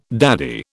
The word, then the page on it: daddy: British English